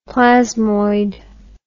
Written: plasmoid

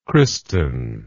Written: Kristin

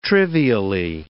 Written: trivially

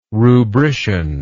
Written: rubrician

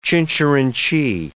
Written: chincherinchee

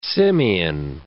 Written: simian

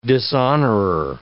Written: dishonorer

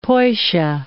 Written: poisha

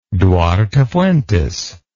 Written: duarte fuentes

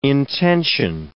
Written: intention

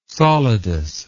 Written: solidus

